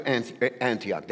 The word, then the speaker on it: Antioch: They deliver the gift, the offering, and they go back to Antioch. There were pastors there in Antioch. Am I saying it right, Antioch? Yes, thank you.